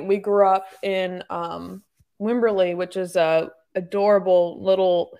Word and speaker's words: Wimberley: W-I-M-B-E-R-L-E-Y, Wimberley, Texas. – Wimberley, Texas.